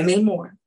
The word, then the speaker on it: Namor: One of my biggest moments was when, how you say his name again exactly? – Namor? – Yeah, Namor.